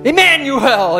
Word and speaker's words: Emmanuel: It rhymes with Samuel. What is that name? It begins with I-M. Emmanuel!